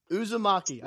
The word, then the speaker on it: Uzumaki: So let's talk a bit about your newest single, Uzumaki.